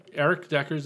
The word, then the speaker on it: Erik Deckers: So Eric Deckers is spelled E-R-I-K-D-E-C-K-E-R-S.